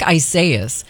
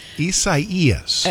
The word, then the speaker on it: Isaias: It looks like Isaias. – Isaias. – I'm kind of assuming that is like a Spanish pronunciation.